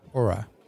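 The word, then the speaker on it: Oura: Dat is die, hoe spreek je het uit? Aura-ring? – Aura. – Aura.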